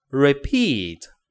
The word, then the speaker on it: repeat: 先看它的中文意思啊，是重复啊，词性是动词，读 repeat，注意一下这个发音啊，re 的时候，这个它是短音，re，然后后面 peat 是长音 p repeat，那你不要读 repeat，不是 p 这样子啊，是 p repeat 啊，repeat 重复啊，动词，OK。